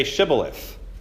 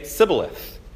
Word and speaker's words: Shibboleth: And if he said no, they would tell him, 'Then say Shibboleth.' But he would say Sibboleth because he couldn't pronounce it correctly.